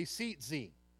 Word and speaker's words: tzitzit: Turn to your neighbor and say tzitzi. It's spelled T-Z-I-T-Z-I-T. So if you're from Kentucky, you might pronounce it T-Z-Z-I-T, but it's not that. It's a tzitzi. And the borders, or the hem of the garment here, is called the kanaf.